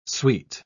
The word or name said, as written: sweet